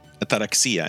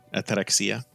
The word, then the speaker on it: ataraxia: Ataraxia, and I'm pronouncing it the proper Greek way, ataraxia.